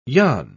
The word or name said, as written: yon